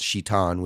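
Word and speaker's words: Shitan (שטן): And so at one point, Lucifer, who the morning star, in Hebrew, Satan is pronounced Shitan, which means the accuser, because God or because Satan kept going to God and accusing him of mistreating humans.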